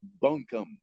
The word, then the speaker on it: Buncombe: And just for the record, it is Bunkum.